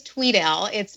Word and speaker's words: Tweedale: It actually is Tweedale. It's spelled phonetically incorrect. So it is spelled with one E, but it's pronounced as if there are two E's.